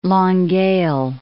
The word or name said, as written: Longueuil